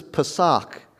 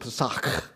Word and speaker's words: Pesach: The word is Pesach. And you can say it like a Jewish person, Pesach. And you can get that in there, Pesach.